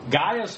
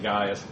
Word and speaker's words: Gaius: it's hard to say because A-I... in Latin and Greek are both pronounced I, so it would be like Gaius if it was Latin, or if it was Greek, either one.